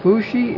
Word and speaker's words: Fuxi: Maybe I'm not pronouncing Fohi correctly, but Fushi is the correct pronunciation for his name.